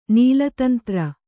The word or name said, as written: ನೀಲತಂತ್ರ